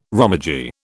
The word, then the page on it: romaji: British English